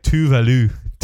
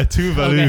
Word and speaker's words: Tove Lo: Yeah, it's that, and Tove Lo, or I think it's pronounced like Tuvalu. – Oh, is it? – Yeah, Tuvalu.